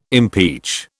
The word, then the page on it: impeach: British English